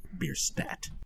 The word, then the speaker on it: Bierstadt: That is, I had to look up how to pronounce it. I believe it's Bierstadt. – And Lagerhaus is with the H-U-S.